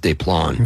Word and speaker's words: Des Plaines: which should be pronounced Des Plaines.